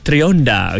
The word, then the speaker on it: Trionda: Maksud Trionda, okay, ejaan dia T-R-I-O-N-D-A, sebutan dia Trionda, okay.